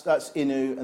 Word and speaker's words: Inu: So, well, that's Inu and that's Neko.